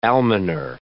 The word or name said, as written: almoner